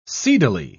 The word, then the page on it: seedily: British English